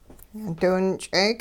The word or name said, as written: diwinch'eek